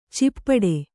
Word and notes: ಚಿಪ್ಪಡೆ: cippaḍe

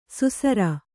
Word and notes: ಸುಸರ: susara

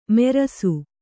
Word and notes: ಮೆರಸು: merasu

